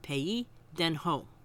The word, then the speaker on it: pays d'en haut: It's spelled P-A-Y-S-D-apostrophe-E-N-H-A-U-T, and it's pronounced pays d'en haut.